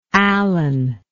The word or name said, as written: Allan